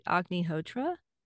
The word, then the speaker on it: Agnihotra: Well, first of all, did I say it right? Agnihotra? – Yes, that's correct. It's said in different ways. It's the two words are sometimes put together in one long word, Agnihotra, and sometimes it's separated into two words.